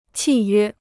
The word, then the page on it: 契约: qì yuē